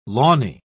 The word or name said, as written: lawny